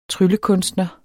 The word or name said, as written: tryllekunstner